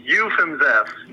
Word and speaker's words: Ufimzeff: Ufimzeff. Gotta get rid of the ooh.